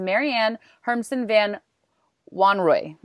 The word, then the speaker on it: Marianne Hermsen-van Wanrooy: So today's guest, I want to make sure I say this right, is Marianne Hermsen-van Wanrooy.